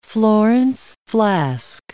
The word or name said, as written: Florence flask